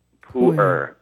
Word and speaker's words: Pu'er: Pu'er.